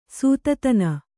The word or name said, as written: ಸೂತತನ